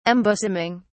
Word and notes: embosoming: British English

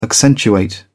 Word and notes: accentuate: British English

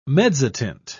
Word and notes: mezzotint: British English

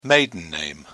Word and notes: maiden name: British English

